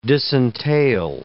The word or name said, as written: disentail